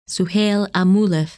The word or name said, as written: Suhail al Muhlif